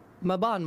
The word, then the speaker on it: Mabon: That's how I phonetically remember his name. I always got to go, Mabon, my boy.